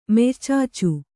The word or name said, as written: ಮೆಯ್ಚಾಚು